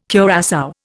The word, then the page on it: curassow: Indian